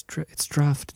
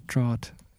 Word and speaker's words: draught: And I had people comment and saying, it's draft, it's not draught. And because I kept saying draught. – Yeah, yeah. – D-R-O-U.